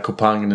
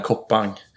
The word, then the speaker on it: Koppang: Jag måste bara dra en parentes om eh, Nina, Nina Kopang, Nina Koppang. Eh, för det är hur hennes namn ska uttalas.